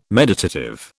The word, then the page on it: meditative: British English